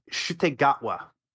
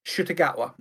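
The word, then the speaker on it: Ncuti Gatwa: Chris, Shutegatwa. – Shutegatwa, okay. – Shutegatwa. I keep wanting to pronounce the N in that, and you don't need to press the N. It's the C that you're pronouncing.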